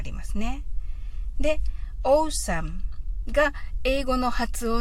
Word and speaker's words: awesome: なんですけれども、これ、オーサムと読みまして、 A から始まるんですけど、あの、意味合いとしては、このオールモーストとかありますよね。